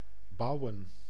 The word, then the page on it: build: /bɪld/